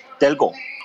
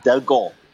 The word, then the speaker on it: Dalgaard: Delgo. – Delgo. – Which is yeah. It's actually interesting because the D at the end is silent. We have a lot of. We have a slew of silent letters.